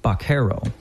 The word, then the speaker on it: vaquero: In Spanish, the letter V is pronounced with a bilabial sound close to the English B, making vaquero sound like vaquero.